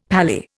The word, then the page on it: paly: Indian